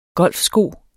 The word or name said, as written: golfsko